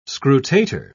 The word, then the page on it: scrutator: British English